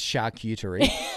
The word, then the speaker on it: charcuterie: It's charcuterie.